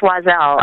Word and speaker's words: Wazell: Yes, Wazell. – Wazell. Okay, got it. – Yep. I sometimes tell people it's like Madame Wazell, but you just take the madam off.